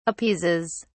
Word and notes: appeases: British English

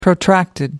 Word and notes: protracted: American English

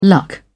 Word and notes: luck: British English

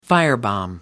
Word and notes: firebomb: American English

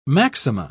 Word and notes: maxima: British English

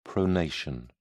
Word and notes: pronation: British English

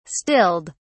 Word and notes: stilled: British English

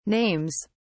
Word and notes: names: British English

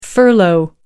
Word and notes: furlough: American English

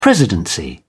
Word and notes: presidency: British English

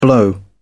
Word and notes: blow over: British English

